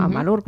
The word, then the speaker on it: Amalur: poco de curiosidad, ¿cómo es Amalur? Exactamente así como se escribe, ¿no? Uh-huh. Amalur, bueno, como se dice.